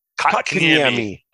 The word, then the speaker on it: Kotkaniemi: Kokonami. I know I said that wrong. – Kotkaniemi.